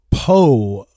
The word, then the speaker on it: Pough: Oliver Pogh, I think is how it's pronounced, P-O-U-G-H.